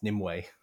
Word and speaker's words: Nimue: It's not Nimue. It's Nimue. – this time featuring Emu. – Rodhull and Nimue. – Rodhull and Nimue. – It's because it's spelled N-I-M-U-E.